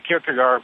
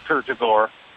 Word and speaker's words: Kierkegaard: Back in my master's work, heard a line from the melancholy Dane Soren Kierkegaard. And the line was, logically systems work, existentially they're improbable. – Interesting. Are you talking about Kierkegaard? – Yep. Kierkegaard. People say Kierkegaard, but it's Kierkegaard.